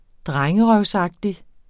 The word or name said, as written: drengerøvsagtig